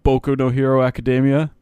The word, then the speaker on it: Boku no Hero Academia: Actually, it's pronounced Boku no Hero Academia.